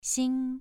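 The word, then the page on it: xing: tone 2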